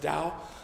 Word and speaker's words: Tao: Lewis talks about it in Abolition of Man as the Tao, or the Dao, sorry. It's T-A-O, but it's pronounced Dao.